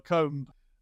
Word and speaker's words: comb: Like, because in English, it's a nuance, or like a bomb or a comb. You don't know it's a silent B because you've only read it and you just know that word is.